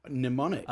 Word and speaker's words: mnemonic: Mnemonic. – Oh, mnemonic. – Not mnemonic. – Not mnemonic. Mnemonic. – Mnemonic. Okay. – But the M is, the first M is silent.